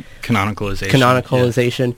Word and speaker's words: canonicalization: Canonicalization.